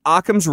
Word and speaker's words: Occam: Occam's razor would tell us. – That's Occam. – Yes, yes. Thank you for the correct pronunciation.